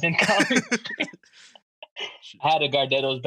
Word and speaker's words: Gardettos: And then next, you guys might go crazy, but I actually really like these, the Gardettos, if that's how you say it.